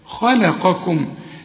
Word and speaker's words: خلقكم: خلقكم هنا اللام عايزة فن في النطق علشان الخاء مفخمة والقاف مفخمة واللام مرققة، حنقول خاء لا قاكم، وبعد القاف كاف وهي قريبة من النطق، واخد بالك طلع كل حرف واديله حقه. خلقكم، وكل ميم بتقف عليها بعدها فيه حاذر إنها تخفى، لازم تظهرها.